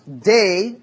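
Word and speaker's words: day (δεῖ): It's pronounced day in Greek, and it means it is necessary.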